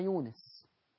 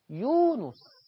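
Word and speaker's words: يونُس: يجي يقولك إيه، أنا النهاردة حفظت سورة يونس، مش حاجة اسمها يونس، يونس ضم النون، يونس.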